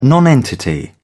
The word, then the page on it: nonentity: British English